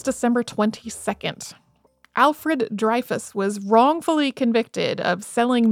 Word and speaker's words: Dreyfus: Most English speakers in the United States say his name Dreyfus and call this the Dreyfus Affair, but in French, it is a lot more like Dreyfus.